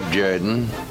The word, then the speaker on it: Jordan: This is Shug Jordan, football coach of the Auburn Tigers.